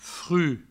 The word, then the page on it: fruch: fru